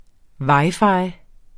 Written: wi-fi

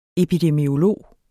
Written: epidemiolog

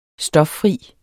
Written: stoffri

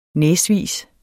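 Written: næsvise

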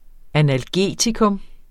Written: analgetikum

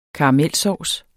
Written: karamelsovs

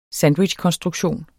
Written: sandwichkonstruktion

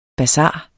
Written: basar

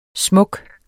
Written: smuk

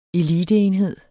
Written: eliteenhed

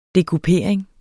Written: dekupering

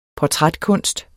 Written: portrætkunst

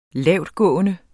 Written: lavtgående

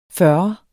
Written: fyrre

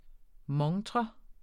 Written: MONTREN

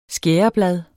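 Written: skæreblad

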